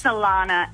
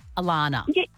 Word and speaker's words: Alana: It's Alana. And I get double N-A-H. I get every letter of the alphabet put at the end of it, but it's just A-L-A-N-A.